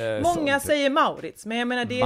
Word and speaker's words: Maurits: Jag känner den som heter Maurits och så uttalas det då. Men... – Ja, det känner jag inte till. Moritz nej. Brukar jag säga. – Moritz är ju säkert det naturliga uttalet.